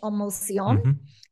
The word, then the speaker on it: En Motion: But it's spelt E-N, like the French, en motion. And so it's Business E-N Motion.